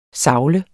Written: savle

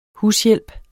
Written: hushjælp

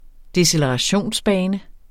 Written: decelerationsbane

